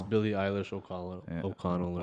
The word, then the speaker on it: Eilish: But the big winner of the night was Billie Eilish.